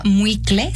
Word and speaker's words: muicle: Se llama muicle, se escribe M-U-I-C-L-E, muicle.